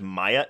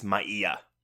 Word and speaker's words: Maia: In Spanish, you pronounce every single... No, you're fine. It's every vowel. So instead of Maya, it's Maia.